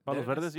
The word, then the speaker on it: Palos Verdes: Is it pronounced Palos Verdes? – Palos Verdes, yeah.